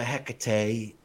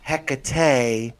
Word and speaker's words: Hecate: But the other thing that makes it really interesting is that there are two pronunciations of the name. – Okay. – So there's Hecate and Hecate. Okay, and both are actually correct, but the mispronunciation, comes down to different syllables.